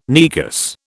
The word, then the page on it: Negus: British English